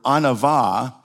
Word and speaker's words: anava: And actually, the Hebrew word in the Old Testament for humility is a word that's pronounced in the Hebrew, anava, and it actually means to occupy your God-given space in the world.